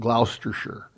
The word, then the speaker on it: Gloucestershire: Born near Dursley, Gloucestershire, or Gloucestershire, actually, is how the British English pronounce it, Gloucestershire.